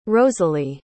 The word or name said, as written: rosalie